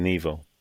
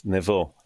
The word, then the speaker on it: Nevo: Probably the British way would be Nevo. – Nevo. – In Hebrew, it's Nevo, actually, but Nevo is fine because that's how it's spelled and it's easy.